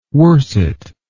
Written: worssett

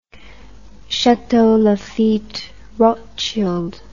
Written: chateau lafite rothschild

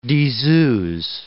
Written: diseuses

